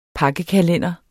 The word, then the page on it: pakkekalender: dansk